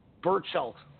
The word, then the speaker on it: Berchelt: Um, November 2nd, we continue to roll Miguel Berchelt, Jason Sosa for Belchert's, Belchert's uh, Berchelt I don't know why I couldn't say that correctly.